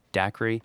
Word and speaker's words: Daqri: It's I believe the company is pronounced Daqri, but it's spelled D-A-Q-R-I, all capital letters, if somebody wants to check it out or look them up online.